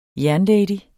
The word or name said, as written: jernlady